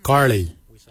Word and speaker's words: Gilyi: Darb is spelled D-H-A-A-B. It is a traditional knife, a darb. For boomerang, we say karli. Karli is the traditional name for boomerang. Karli is spelled G-I-L-Y-I, karli. For the traditional stone axe, we say koch, koch. Koch is spelled G-O-O-D-J, koch.